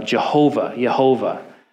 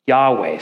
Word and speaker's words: Yahweh: And scholars used to think it was pronounced Jehovah. And we can now know that the closest that we can make of the actual name in light of the missing vowels is pronounced Yahweh.